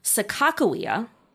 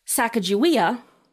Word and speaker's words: Sacagawea: So the difference there being the K versus G. – Right. – In the third syllable, or second syllable, whatever. – Yes. Sacagawea, and the one that we most recognize.